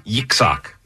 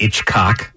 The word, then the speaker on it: Yitzhak: Yitzhak. – I've heard of him. Yeah. Yitzhak. Somebody out there knows Yitzhak. – Yeah. – I think it's Hitchcock. – No, it's Yitzhak. It's not Hitchcock.